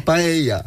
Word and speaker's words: paella: Pisze się paella, czyta się paella.